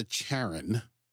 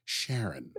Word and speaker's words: Charon: they call it the Charon. It's a hard C. I wrote it down, but I've always pronounced that Charon.